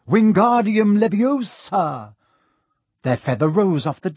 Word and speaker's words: Wingardium Leviosa: "Wingardium Leviosa," he shouted, waving his long arms like a windmill. – "You're saying it wrong," Harry heard Hermione snap. "It's Wingardium Leviosa. Make the gar nice and long."